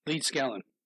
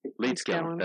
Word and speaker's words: Leedskalnin: So in my research, I focused on Edward, how do you pronounce his last name? – Leedskalnin. – Leedskalnin, that's it.